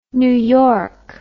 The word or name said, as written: New York